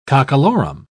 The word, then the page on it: cockalorum: British English